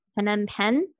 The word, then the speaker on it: Phnom Penh: Phnom Penh in oh, Cambodia.